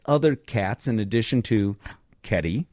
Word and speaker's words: Kedi: Kitty? – That's a word. – Well, no, it's spelled K E D I. – Oh, Keddie, all right.